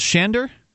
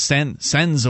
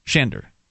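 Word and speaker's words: Sándor: So S-Z is pronounced with an H. Shander. – Like an S-H, yes. – Like Zorro. – Very much like an S-H. – So like Zorro, yes. – Shander is your pen name?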